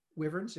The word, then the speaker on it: wyvern: So, you mentioned, was it Wyvern? Wyvern? Is that how you pronounce it? – Wyverns, yeah.